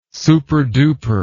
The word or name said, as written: superduper